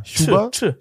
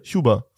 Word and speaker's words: Chuba: Ist es so ein Sch oder so ein Sch? Es ist ja CH. – Ja, Chuba. Chuba. Chuba. – Wie Tschüss. – Tschüss. Nina Chuba.